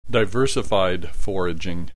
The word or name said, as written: diversified foraging